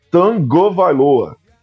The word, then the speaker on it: Tagovailoa: A gente fala Tagovailoa, né? Porque a gente aportuguesou, mas a pronúncia correta é Tagovailoa.